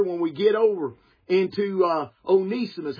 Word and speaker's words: Onesimus: And so I thank God, Onesimus, I say Onesimus a lot, but it's the correct pronunciation of it is Onesimus. Onesimus.